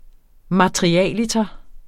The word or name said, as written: materialiter